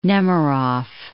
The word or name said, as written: Nemerov